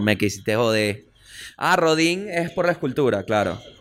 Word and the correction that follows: Rodin: ¿Cómo se pronuncia? Rodán. Ah, ahí decía Rodin, me vas a disculpar.